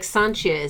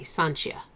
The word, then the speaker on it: Sancia: Like Sanchia is S-A-N-C-I-A, Sanchia.